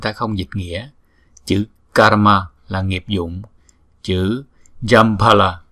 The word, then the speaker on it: karma: Karma, karma. Chữ K này chúng ta đọc là K. Karma, karma. Chúng ta phải đọc nghe chữ R nữa nha.